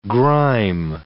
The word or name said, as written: grime